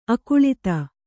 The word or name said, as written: ಅಕುಳಿತ